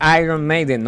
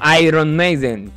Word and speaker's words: Iron Maiden: Recuerden que se dice Iron Maiden, no Iron Maiden, porque la R ahí es como que así es la verdadera pronunciación, ¿sí?